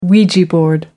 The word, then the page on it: ouija board: American English